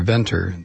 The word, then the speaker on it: Venter: In a first, Craig Bentner, or Venter, the guy I'm talking about here, has made a synthetic cell from scratch.